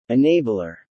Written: enabler

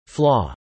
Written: flaw

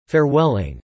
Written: farewelling

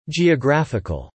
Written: geographical

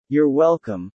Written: You're welcome

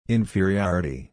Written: inferiority

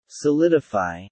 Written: solidify

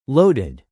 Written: loaded

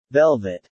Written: velvet

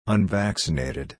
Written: unvaccinated

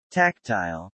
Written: tactile

